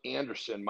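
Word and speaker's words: Andersen: So if you want to connect with me, my Instagram, which is at the John Anderson, my name is spelled different.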